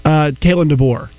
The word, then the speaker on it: Kalen DeBoer: Kalen DeBoer.